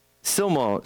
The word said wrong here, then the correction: Siloam: I can't pronounce it. Siloam. There we go. Siloam.